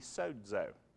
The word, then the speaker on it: sozo: So that text of S-O-Z-O, a lot of people pronounce so-zo, but actually, again, it's transliterated. It's a Greek word that when you speak it in Greek has a little quiet D sound in it.